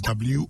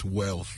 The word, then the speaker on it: worth: So I don't know how people find L in that, but look, it is WORTH. Worth it. – Not wealth. – No. Let's not go there. – So now I know how to say it is worth it, not wealth it.